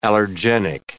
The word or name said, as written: allergenic